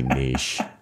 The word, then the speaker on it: niche: So my problem is I had a podcast for years spelled N-I-T-C-H. So that my mouth just doesn't want to say niche. – It's okay. You can say it however you like. – I've looked it up. Both pronunciations are allowed.